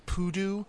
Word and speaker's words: P00DOO: which you pronounce as Poodoo.